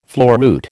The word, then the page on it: floruit: American English